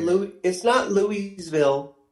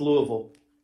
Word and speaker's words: Louisville: It's not Louisville. It's Louisville.